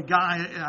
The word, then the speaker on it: Gaia: And her divine name is Gaia. Or Gaia. Really, Gaia, I found, is the most prominent pronunciation for her, or for that name.